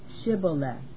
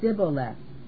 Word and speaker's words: Shibboleth: Then said they unto him, Say now, Shibboleth. And he said, Sibboleth, for he could not frame to pronounce it right.